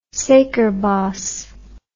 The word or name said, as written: suikerbos